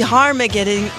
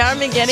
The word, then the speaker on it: Armageddon: Toi, t'as dit Armageddon... C'est Armageddon.